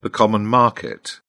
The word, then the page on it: common market: British English